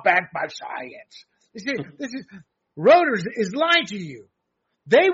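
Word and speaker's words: Reuters: Too much rotors. Rooters. How do you say it? G, I always screwed up. – Rooters, yeah.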